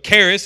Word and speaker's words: charis: So the word for grace in Greek is charis or charis. Or different people pronounce it different ways. I can't speak Greek. I'm still working on English. But charis is how I pronounce it.